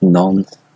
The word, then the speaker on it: Nantes: It's near, ironically, I don't know how you pronounce that. Apparently it's pronounced Nantes, but, sorry. – Nantes.